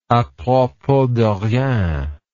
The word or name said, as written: apropos de rien